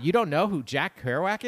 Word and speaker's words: Kerouac: Going back to this Jack Kerouac, is that how you say it? – Sure. – Well, how do you say it? – Kerouac.